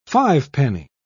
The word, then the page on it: fivepenny: British English